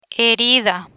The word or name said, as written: herida